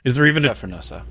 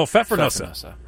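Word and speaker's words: Pfeffernüsse: Is it Pfeffermousse? Pfeffermusse. Pfeffermusse. Pfeffermousse? Do we have somebody? Do we have the sexy lady to pronounce it? Is there even Pfeffernusse? – Oh, Pfeffernusse. Pfeffernusse. – Pfeffernusse. – Okay, Pfeffernusse. – Pfeffernusse. – Okay, got it. So Pfeffernusse. Pfeffernusse translates from German to English to peppernut, and that's what these cookies are made of.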